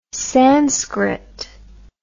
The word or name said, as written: sanscrit